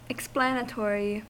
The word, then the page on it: explanatory: American English